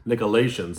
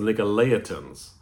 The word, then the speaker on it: Nicolaitans: I said it wrong on Sunday. I want to apologize. I said the Nicolaitans. It's the Nicolaitans. I want to make sure I pronounce that correctly.